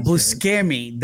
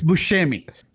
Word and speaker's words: Buscemi: Listen, the fact that you pronounced that Buscemi, that really bothers me. It's Buscemi.